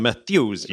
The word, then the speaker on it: Methuselah: The Methuselah Ship, did I pronounce that right? – Yeah, the Methuselah Ship.